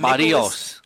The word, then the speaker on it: Berríos: You got guys like Jose, I'm going to say it right, Scott, Berríos. We got Barrios. – Barrios. We got Mikolas. – Barrios. – Yeah, like the Barrio, okay? Like El Barrio.